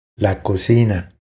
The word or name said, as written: La cocina